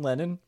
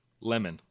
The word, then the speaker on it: Lemon: But I always get his name wrong. Don Lennon. – Lemon.